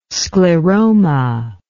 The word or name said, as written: scleroma